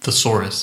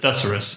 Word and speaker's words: thesaurus: Frank doesn't pronounce it thesaurus. He pronounces it thesaurus.